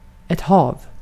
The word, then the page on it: hav: /hɑːv/